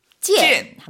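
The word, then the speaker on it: 찐 (见): 만날 견자는 발음을 이렇게 했었어요. 찐.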